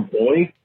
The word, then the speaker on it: buoy: And last Wednesday, you guys were having a discussion about whether it's pronounced buoy or boy. I have a buddy who pronounces it boy, and his reasoning is that you pronounce buoyancy as buoyancy, not buoyancy.